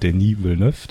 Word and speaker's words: Denis Villeneuve: Und dann hat mir auch sehr gut gefallen, eben Blade Runner 2049 äh, von Denis Villeneuve, den ich jetzt diesmal richtig ausspreche.